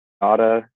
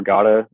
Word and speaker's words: Gotta: Well, on TikTok, it's Jake Gotta, just at Jake underscore Gotta. My last name is spelled G-O-T-T-A, if that was hard to figure out.